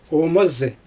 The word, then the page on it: oomazy: Xyzyl